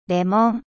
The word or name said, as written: citron